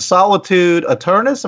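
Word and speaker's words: Solitude Aeternus: Solitude Eternus. Am I saying that right? It was on Roadrunner Records. – Yes. Mm-hmm.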